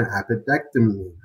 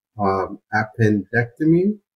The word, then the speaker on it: appendectomy: Appendectomy. Did I say that right? Yes. – Good. He's going to have his appendix removed. You missed an N the first time, but appendectomy.